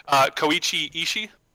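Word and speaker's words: Koichi Ishii (石井浩一): Koichi Ishii. That's how you pronounce it. I'm going to copy paste it. – I don't recognize it. It sounds like you're pronouncing it correctly, yeah, but I don't recognize the name.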